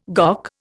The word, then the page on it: GOC: Indian